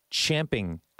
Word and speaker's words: champing: Evidently, champing at the bit is the correct way to say it. However, both are technically okay, but if you were to pick between the two, it's champing. So it's when a horse grinds its teeth on a piece of metal yeah.